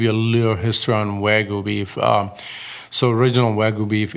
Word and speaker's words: Wagyu: But I've never really experienced Wagyu beef, which is spelled W-A-G-Y-U. – Yes, ma'am. – I guess I'm saying it right. – Yes, you are.